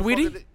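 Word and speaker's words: Saweetie: Cardi B, Doja, Lizzo, Meg Thee Stallion, Salty. Salty or Shorty? – Whatever Sweetie? – Yeah, there you go. – Yeah, Sweetie, guy. – The shit is spelled S-A-W.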